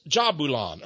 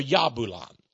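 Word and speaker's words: Jahbulon: 3 times 3, it's Jabulon, or Yabulon, if you want to have a different pronunciation of the J.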